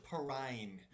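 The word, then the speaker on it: Perine: Pick one, PPR, Sermon, Gainwell, Gaskin, or is it Perrine? I don't even know how to say his last name. – Samaj Perrine.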